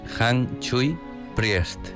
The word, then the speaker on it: Han Chui Priest: Es Han Chui Priest.